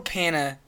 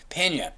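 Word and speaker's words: Peña: Michael Pena, I'm saying it wrong. How do you say his name? – Pena. All right. Michael Pena.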